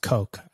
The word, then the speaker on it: Koch: I think that's pronounced Coke, I think.